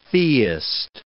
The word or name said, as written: theist